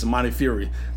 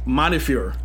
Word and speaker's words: Montefiore: How do you say it? – Montefiore. – Montefiore. – So the e, yeah. So you're not pronouncing the e. – God forgive me, because I thought it was Montefiore. – Like the e, like we're, we're we're putting we're, we're we're pronouncing that e very hard when we say it.